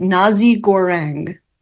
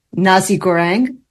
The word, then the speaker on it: nasi goreng: The fourth one is called paella, P-A-E-L-L-A. I've pronounced it horribly, nevertheless, paella. – That's right. – Number five is Nasi Goreng, N-A-S-I, G-O-R-E-N-G, Nasi Goreng.